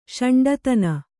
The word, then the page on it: ಷಂಡತನ: ṣaṇḍatana